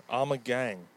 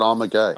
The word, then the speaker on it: Omegang: No, it's gang. Omegang. – Oh, I thought you said Omegay. – Omegay. Amadeus. Amadeus. Amadeus. Amadeus. – Amadeus. – Amadeus. Where are they at? Omegang.